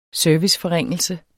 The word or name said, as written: serviceforringelse